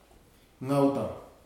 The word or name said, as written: tanna